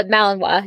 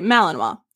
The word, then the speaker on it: Malinois: Malin, Malinois? Oh, uh, Malinois. Yeah. Okay, Malinois.